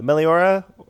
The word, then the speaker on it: Meliora: And I've gone into listening to Meliora. Am I saying that right? – Yep.